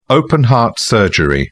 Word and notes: open-heart surgery: British English

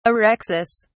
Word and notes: orexis: British English

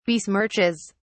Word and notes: besmirches: British English

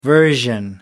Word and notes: version: American English

